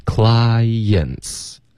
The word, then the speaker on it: clients: 它是两个音节 OK clients 两个音节 clients 别发成 clients OK 这一个音节的发音呢对方老外会听不懂的容易误解 OK